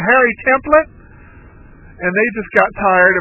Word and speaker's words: Templet: It's spelled T-E-M-P-L-E-T. It is a French Cajun, that is from her French Cajun heritage. Her parents, it comes from her father, her parents did not mind the fact that the anglicized version of it was pronounced Templet. She didn't accept that. She wanted to be pronounced the way that a French person, a French speaker, would pronounce it. So she pronounced it Templet.